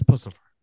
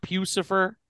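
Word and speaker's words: Puscifer: It is Pussifer. – Yeah. – It's not Pucifer. – Pussifer. – What the fuck? – I've never heard anyone call him Pucifer. – I'm 90% sure it's Pussifer.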